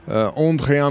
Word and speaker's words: André: André, I'm going to pronounce it in a French way because he is essentially, or he was until 2 years ago, French, of Maltese origin or heritage, as we'll hear.